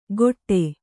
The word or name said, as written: ಕೊಟ್ಟೆ